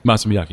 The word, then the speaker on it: Masa Miyake: Am I saying that correctly? – Masa Miyake, yeah.